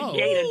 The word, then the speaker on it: Jada: Because they always spell it J-A-D-E. – Woo, woo. – No, it's Jada, woo, just like Pinkett.